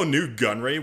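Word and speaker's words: Nute Gunray: Even though Newt Gunway was a... Newt Gunway. I'm going to pronounce him like that from now on. Newt Gunway. Even though Newt Gunray was a prominent member of the Separatists, the Trade Federation was proclaimed neutral within the Clone Wars.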